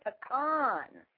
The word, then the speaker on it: pecan: I know you gotta be kicking it in the sun, cause you butter be tan. – No, you gotta say it right. Butter pecan. – Butter pecan. Butter pecan. – Pecan. Right.